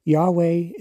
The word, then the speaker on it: Yahweh: Yahweh is not God. The real pronunciation is Yahweh.